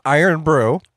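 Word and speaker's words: Irn-Bru: Apparently, it's pronounced Iron Brew. – Yay. – I'm with you. IRNBRU. – There's no O.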